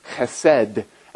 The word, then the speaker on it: chesed: It's based on a Hebrew word that's pronounced chesed, and it's used dozens and dozens of times in the Old Testament.